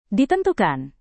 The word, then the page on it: ditentukan: Indonesian